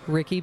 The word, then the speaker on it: Rickey: I am on Twitter at Ricky Bevington, and I'll spell it R-I-C-K-E-Y, because people think that it's a nickname. It's not a nickname.